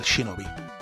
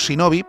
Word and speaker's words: Shinobi: que es el Shinobi. Nosotros le llamamos Shinobi, pero era Shinobi, o sea, la, H se pronunciaba.